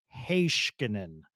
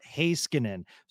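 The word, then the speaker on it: Heiskanen: You know, the other one that Sean says, because my co host went over to Finland and he said, no, this is the correct pronunciation Heiskinen, not Häskinen."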